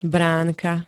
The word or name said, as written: bránka